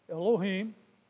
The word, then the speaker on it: Elohim: In the Old Testament, when we read the word God, it has been translated from the Hebrew word Elohim, pronounced Elohim.